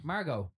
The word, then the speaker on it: Margot: Margot. Margot. – It's Margot. – Not a hard T. – The T is silent.